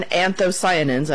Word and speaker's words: anthocyanins: And those American anthocyanins, and I'm assuming I'm pronouncing that correctly.